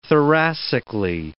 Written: thoracically

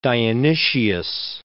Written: Dionysius

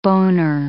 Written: boner

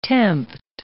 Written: tempt

